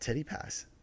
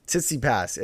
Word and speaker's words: Tsitsipas: Okay, first... Tsitsipas. It's... You got to put a CC in there. – Tsitsipas. – It's like... What is it? T-S-I-T-S... No. T-S-I-T-S-P-A-S.